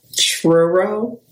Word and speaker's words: Truro: It's spelled T-R-U-R-O. Truro? Truro. – That sounds right.